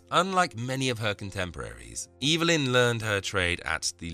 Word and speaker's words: Evelyn: Many pronounce it Evelyn. She always insisted that her name be pronounced Evelyn.